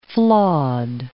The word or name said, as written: flawed